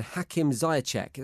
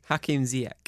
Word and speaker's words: Hakim Ziyech: They are Jadon Sancho and Hakim Ziyech. Am I saying that right? – No. – No, go on. – Shocking. – For a change. – Shockingly. – Do I even need to ask? – Hakim Ziyech. – There we go.